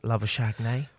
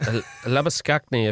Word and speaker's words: Labuschagne: Well, how do you say it? Labuschagne? – Well, it depends. It's Labuschagne if you're from South Africa, but when you're scoring all these runs, they talked about him being like champagne, so they changed his name to Labuschagne.